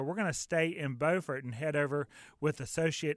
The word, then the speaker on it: Beaufort: And right after that comes Beaufort. Of course, it's oftentimes mixed up for people not in the Carolinas with the same spelled city in South Carolina, but the pronunciation's different. Beaufort.